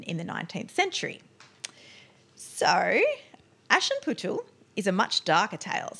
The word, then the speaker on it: Aschenputtel: That story called, and I'm so going to screw this up. – Go on. – Aschenputtel. – Nice. – It's spelled Aschenputtel. – All right, I'm not going to. – Aschenputtel. Yep.